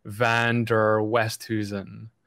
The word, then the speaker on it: Van der Westhuizen: Yeah, I told Jeremy when he asked me, I said, you can pronounce it any way you want. So I'm from South Africa, so my last name is, I guess, Dutch heritage, and you say it by saying Van der Westhuizen, right?